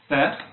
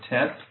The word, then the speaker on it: Tet: The Hebrew letter is Thet or Tet, depending on who you're talking to and how they pronounce it.